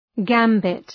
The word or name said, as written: gambit